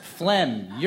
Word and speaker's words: phlegm: Mucus, vomit, phlegm, urine. – Phlegm. – And the silent letter? – G.